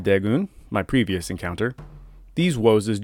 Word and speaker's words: Woses: It's just an odd and pleasing sounding word, Woses. It's like you're mispronouncing it, but you're not.